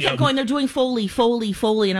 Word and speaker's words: Foley: And I didn't know this, but it's called Folly. Foley? – Foley. – Foley. Foley oh, I, am even saying it wrong.